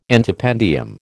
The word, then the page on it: antependium: American English